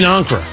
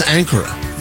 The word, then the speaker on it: Ankara: Tony Katz today, so dominated by what happened with President Trump in Ankara. I always thought it was Ankara, but it's pronounced Ankara.